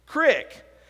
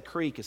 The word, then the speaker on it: creek: You know how she pronounces the word creek? Crick. And if I heard her say crick, how would I write it? C-R-I-C-K and not C-R-E-E-K. How many of you know that the right way to say creek is creek?